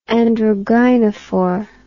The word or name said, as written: androgynophore